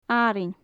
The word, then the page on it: arainn: gaelic